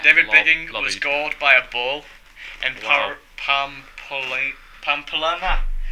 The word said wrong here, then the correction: Pamplona: whatever in 2003. – Pamplona. – Yeah. Pamplona. Mr. Biggings, what a name.